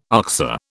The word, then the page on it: oxer: British English